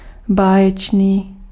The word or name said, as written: extraordinaire